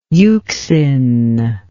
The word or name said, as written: euxine